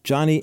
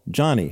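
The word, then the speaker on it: Joni: own Dr. James Dobson and his dear friend, Johnny Erickson Tada.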